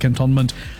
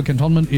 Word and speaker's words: Cantonment: Hey, I know some of you cocked your heads just now when I said Cantonment. No, so apparently that's how it's supposed to be pronounced. That's how we've been trained to pronounce it in radio. That's the original pronunciation. It's a name of an individual, and it's cantonment. To be cantoned. So cantonment is the pronunciation of it.